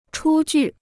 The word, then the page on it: 出具: chū jù